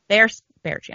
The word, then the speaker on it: Baertschi: Sven Bertsky, is that how you say his name? Bertsky. – Ter- Baertschi. I'm terrible with names. Baertschi.